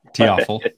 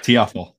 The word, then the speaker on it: Taofele: Um, and then visiting with him, Talafel, is that how you say it? Correct me. We were trying to do this beforehand and I've forgotten. Taofele. That's right. Taofele.